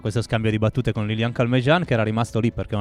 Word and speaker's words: Lilian Calmejane: Scusa, vorrei che tu lo pronunciassi come si conviene nel frentaccio. Lilian Calmejean. – Sì, no, l'ho detto bene, va bene come l'ho detto. Lilian Calmejean, che comunque parla un ottimo inglese, per cui ci sorprende così.